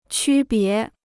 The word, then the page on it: 区别: qū bié